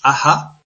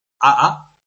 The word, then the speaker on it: Aha: Será su hijo, probablemente Horus Aha o Horus Aa, porque la H, aunque es aspirada, depende de la pronunciación que se use, puede ser muda.